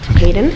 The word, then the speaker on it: Haydn: H-A-Y-D-N, Hayden.